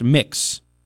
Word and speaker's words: Mx: My prefix, is MX period, pronounced mix. So instead of Mr. Bressack or Miss Bressack, it's Mix Bressack.